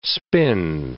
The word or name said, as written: spin